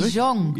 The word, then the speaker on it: Qigong: Qigong.